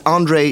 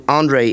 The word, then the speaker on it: Andrej: It's Andre Dukovsky. – Could you spell that for me? – Yes. It's Dukovsky with a Y at the end. Dukovsky. And Andre is A-N-D-R-E-J. – D-R-E-J.